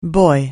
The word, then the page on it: buoy: anglais